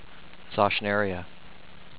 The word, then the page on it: Zauschneria: zaush-NER-ii-ah